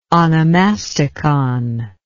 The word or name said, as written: onomasticon